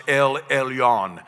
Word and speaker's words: El Elyon: It's pronounced El Elyon.